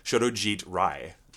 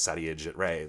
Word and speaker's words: Satyajit Ray: I was actually reading, I'm reading this book on, I'm going to pronounce this correctly, Shodojit Rai. The otherwise known as Satyajit Ray, the Indian Bengali filmmaker.